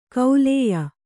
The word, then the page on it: ಕೌಲೇಯ: Kannada